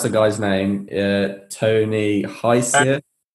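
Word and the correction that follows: Hsieh: Tony Shea. – I was dead close. – It is spelled weird. – Yeah. H-S-E-I-H. – Yeah. Pronounce Shea.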